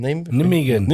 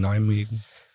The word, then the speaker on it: Nijmegen: Klar. N-I, nicht Y, sondern J. – J, genau. – Genau, ja, deswegen Nijmegen.